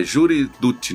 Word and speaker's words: Jury Duty: que é aquele Jury Dirty. É Jury Duty, né? A, é, pronúncia Jury Dirty,